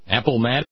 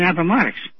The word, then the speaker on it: Appomattox: A-P-A-L-A-P-A-L-M-A... T-O-X. – Apple Matt. – Appomattox. To me, it's always been Appomattox. – Yeah. – And I said, they pay somebody to read this news? – Well, he just had his emphasis on the wrong syllable.